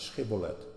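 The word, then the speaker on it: sjibbolet: En toen moesten ze dat uitspreken. En dat konden ze niet. Ze konden de CH niet zeggen. Schibbolet.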